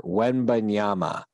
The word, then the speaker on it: Wembanyama: Oh yeah, Victor Wembanyama.